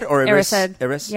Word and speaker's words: Erised: How do you say Erised? – Erised.